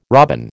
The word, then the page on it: robin: American English